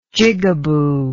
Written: jigaboo

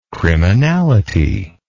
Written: criminality

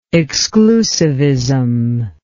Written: exclusivistic

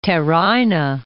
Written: Teraina

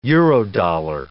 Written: Eurodollar

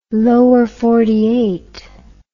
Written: Lower Empire

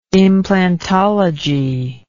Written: implantology

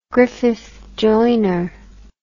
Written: Griffith Joyner

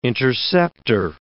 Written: intercepter

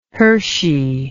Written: Hershey

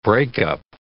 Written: breakup

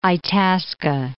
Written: Itasca, Lake

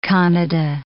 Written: Kannada

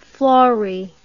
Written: florrie

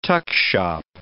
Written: tuckshop

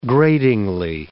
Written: gratingly

